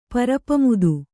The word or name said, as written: ಪರಪಮುದು